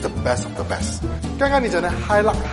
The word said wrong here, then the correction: Hilux: Hilux 其实真正的读音呢是 Hilux。